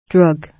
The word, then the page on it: drug: British English